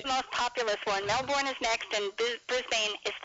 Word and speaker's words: Melbourne: Pronounce Melbourne like we pronounce it, incidentally.